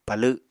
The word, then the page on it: palw: Apatani